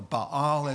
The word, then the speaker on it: Baal: He tears down the neighborhood shrines to Baal, or Baal, as you should pronounce his name.